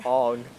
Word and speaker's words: Aang: Aang.